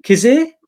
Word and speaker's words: Kizzire: Kizzire.